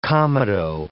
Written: comedo

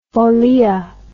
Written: folia

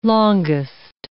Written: longest

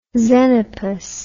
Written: xenopus

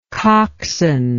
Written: cockswain

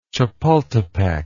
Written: Chapultepec

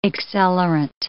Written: accelerant

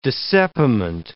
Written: dissepiment

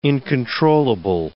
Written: incontrollable

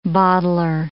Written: bottler

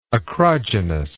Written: acrogynous

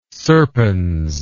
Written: serpens

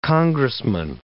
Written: congressman

